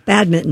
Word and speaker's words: badminton: You don't pronounce that N? – You do not. – No, badminton.